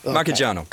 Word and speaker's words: Marchigiano: I'm not going to try and pronounce the Museo del Synth Marchigiano. Is that Marchigiano. – Close.